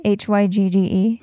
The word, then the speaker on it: hygge: No, it's hygge. I listened to an audiobook about it.